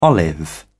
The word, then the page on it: olive: British English